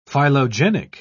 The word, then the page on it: phylogenic: British English